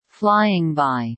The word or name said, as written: flying by